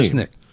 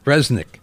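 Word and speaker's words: Rzeznik: Rocker John Resnick of the Goo Goo Dolls. How do you say that name? – Resnick. – What? – Resnick. – What is it? It's R-Z-E-Z-N-I-K.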